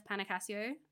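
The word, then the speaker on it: Panecasio: Well, on most social media, I am simply just my name, which is at Steph Panicasio, spelled like window pane Casio watch for people who are confused. I have to say that every time I'm on the phone and somebody's like, what?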